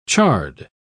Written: charred

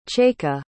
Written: chaika

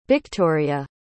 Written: bhictoria

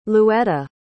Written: louetta

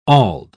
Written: auld

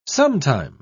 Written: sometime

